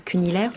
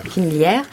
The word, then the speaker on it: Cunillière: Ça, il faut dire Cunillière, c'est comme ça que ça se prononce. – Cunillière. – Cunillière.